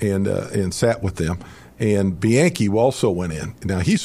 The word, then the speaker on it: Bianchi: Al Bianchi. When Bud Olson went into. – Sounds like two syllables.